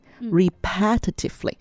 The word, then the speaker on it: repetitively: 我就读正确的发音哈，正确的发音是 re repetitively。 repetitively，那他说 repetitively 跟另外一个字 repeatedly 到底有什么区别？